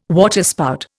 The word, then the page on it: waterspout: Indian